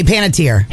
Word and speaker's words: Panettiere: Hayden Panettiere. That's what I say, Panettiere.